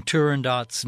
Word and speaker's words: Turandot: the current practice of pronouncing the final T in Turandot's name is incorrect,